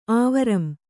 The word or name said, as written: ಆವರಂ